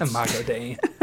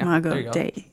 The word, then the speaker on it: Imago Dei: Imagio Dei. – I've butchered it. – Imago Dei. – One of these days, I'm going to learn actually how to say some of these words. – I-M-A-G-O. Imago Dei. Yeah.